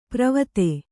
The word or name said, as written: ಪ್ರವತೆ